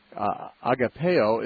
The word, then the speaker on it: agape: Agapeo is sometimes said to me, which we pronounce agape, is sometimes said to be distinctly that divine, selfless, unconditional love that is totally self-sacrificial.